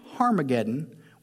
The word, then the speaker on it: Harmageddon: So they assembled the kings at the place called in Hebrew Armageddon, which is pronounced Harmageddon, which sounds like a pirate said it.